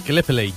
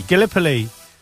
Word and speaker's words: Gallipoli: Gallipoli. – Gallipoli.